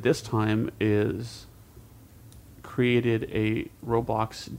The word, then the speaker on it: Roblox: It's Roblox? Roblox? – Yeah, Roblox.